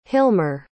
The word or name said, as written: hilmer